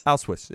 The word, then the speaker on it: Auschwitz: they go, and now I wonder if I'll shock you on this one or not, but they go to Auschwitz. Yeah, I'm saying that right? – Auschwitz, yeah.